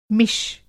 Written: मिष

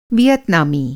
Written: वियतनामी

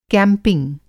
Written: कैंपिंग

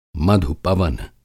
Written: मधु-पवन

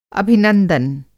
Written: अभिनंदन

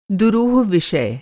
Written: दुरूह

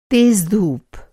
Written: धूप

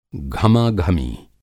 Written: घमाघमी

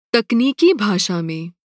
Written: भाषा

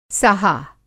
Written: साहा

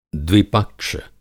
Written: द्विपक्ष

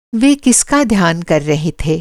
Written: ध्यान

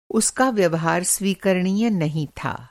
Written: स्वीकरणीय

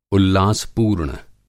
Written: उल्लासपूर्ण

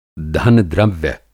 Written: धन-द्रव्य

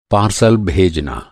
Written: पारसल

